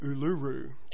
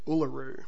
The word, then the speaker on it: Uluru: The most common mistake foreigners make with this one is pronouncing it Uluru, due to all the U's in it. Listen again and see the difference. Uluru. Note that the first syllable is pronounced the same way as "uh" in book.